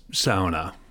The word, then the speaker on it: sauna: Well, the way to go is sauna, as in the Finnish pronunciation. It's really the one Finnish word that is generally well known in English, and so I think we should give tribute to them.